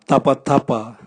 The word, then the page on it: tabwatabwa: Raga